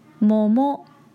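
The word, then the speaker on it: もも: 那今天我们要教大家的是桃子的日语，摸摸摸摸摸摸。这边要注意一下它的重音，千万可不能念成摸摸，念错了就变成大腿而不是桃子了。另外吃桃子的日语是摸摸を食べる，摸摸を食べる，摸摸を食べる。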